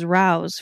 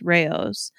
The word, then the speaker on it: Rao's: I thought it was Rao's for a long time, and then I saw a commercial and was shook that is pronounced Rao's.